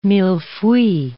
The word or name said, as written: mille-feuille